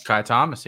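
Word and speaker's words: Ky Thomas: Am I saying that right, Kai Thomas? – Kai Thomas, yes, sir.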